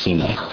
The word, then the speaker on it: Sinai: It's the same root word for sine, which we say is Sinai, but it's pronounced sine.